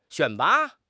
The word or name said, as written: 选拔